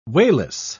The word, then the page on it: wayless: British English